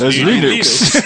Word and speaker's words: Linux: And I pronounce Linux as Linux.